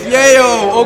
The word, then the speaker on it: yayo: They say Lelo. Is it Lelo? Or is it, do they say Yayo? Is it Yayo? – Yeah. – Oh, that's how you pronounce it. It's Yayo.